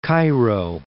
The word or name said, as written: Cairo